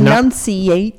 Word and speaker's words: enunciate: If I want to slow down my speaking, because Gotta this is enunciate. not enunciate. That's right. You put the wrong emphasis on the wrong syllable.